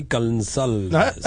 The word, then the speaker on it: Gonsalves: Ellie Gonsalves. – I believe it's Ellie Gonsalves.